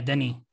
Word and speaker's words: Denis: But when you have Denis Villeneuve directing something with Is that the proper pronunciation? – Yeah, Denis.